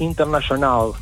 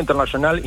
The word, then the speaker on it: Internationale: Bueno, la banda se llama The Last International. ¿Y por qué digo internacional y no international? Porque tiene una E al final y yo supongo que se pronuncia así.